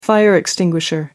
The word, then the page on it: fire extinguisher: American English